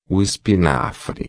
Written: espinafre

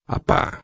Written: pá

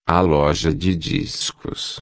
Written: loja de discos

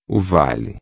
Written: vale

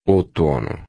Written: outono

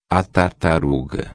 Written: tartaruga

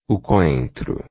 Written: coentro

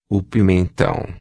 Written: pimentao

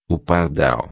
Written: pardal

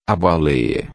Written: baleia

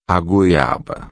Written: goiaba

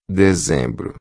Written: dezembro

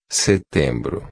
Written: setembro

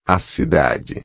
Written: cidade

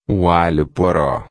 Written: alho-poro

